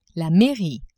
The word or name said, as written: mairie